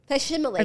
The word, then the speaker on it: facsimile: And it's pronounced... – Shh, wait, shh. – Facsimile. – Facsimile.